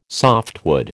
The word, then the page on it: softwood: American English